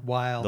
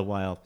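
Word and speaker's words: Weill: The doctor, it was Dr. Fish from the, how'd you pronounce it? The Wheel? – Weil. – The Weil.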